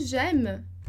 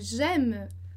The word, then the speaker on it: j'aime: E a gente tem também em j'aime. Eu gosto, j'aime, tá? Então, lembrar ali que o AI sempre vai fazer o som de E, que tá entre barras.